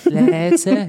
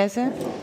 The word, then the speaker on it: leise: Die, die lernen, das Ei, die machen das ähm, meistens falsch, weil sie es lang aussprechen. Leise. Da haben sie schon an sich die richtigen Vokale. Das Ei ist ein A und ein E, nicht ein A und ein I. Leise. Nur, das ist viel zu lange. Das leise gehört immer kurz ausgesprochen.